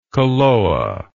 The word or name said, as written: koloa